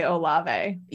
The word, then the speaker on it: Olave: O-L-A-V-E. – I would say Olave.